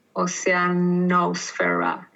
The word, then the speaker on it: Oceanosfera: And Oceanosfera is spelled ocean and then an O-S-F-E-R-A. Oceanosfera is how you say it in English.